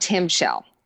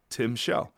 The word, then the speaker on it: timshel: Okay, so and it's called called Tim Shell. – Spell it. – T-I-M-S-H-E-L. – Oh, it's that. Okay, Tim Shell.